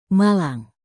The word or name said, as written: malang